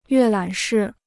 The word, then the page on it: 阅览室: yuè lǎn shì